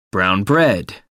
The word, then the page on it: brown bread: American English